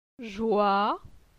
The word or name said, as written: Joi